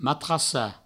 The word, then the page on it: matrassar: matrassa